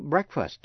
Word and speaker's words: breakfast: Nhưng nhớ là chữ breakfast, bữa ăn sáng. Breakfast thì EA phát âm là E. Quý vị nghe lại. To break. Breakfast.